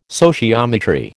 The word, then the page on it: sociometry: American English